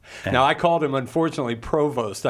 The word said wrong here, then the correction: Prevost: I mispronounced his name.